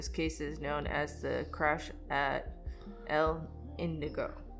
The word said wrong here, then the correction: Indio: No. – Indio.